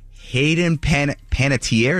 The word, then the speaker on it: Panettiere: Hey, 3 months after exposing Hollywood and the music industry for being the scumbags that they are, Hayden Panettiere, is that how you pronounce her yes.